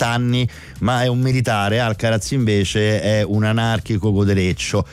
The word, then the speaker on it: Alcaraz: Innanzitutto Alcaraz comunque è la pronuncia esatta è Carlos Alcaraz. – Ah, cioè te devi mangiare la lingua mentre lo... – Sì, Alcaraz. Cioè devi sputare, ok.